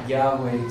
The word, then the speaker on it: Yahweh: But Yahweh seems to be the most likely pronunciation of that, because Jehovah basically is a result of using Yahweh, the word Yahweh, with the vowels of Adonai, I believe it was, to combine, and then it becomes Jehovah.